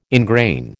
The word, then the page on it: ingrain: American English